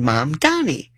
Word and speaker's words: Mamdani: His name, Mom and Dad, Mamdani. My mom, Donnie.